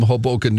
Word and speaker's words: Hoboken: The financial planner from Hoboken, New Jersey. Did I say it right? – Mm-hmm. – Yeah. Yep.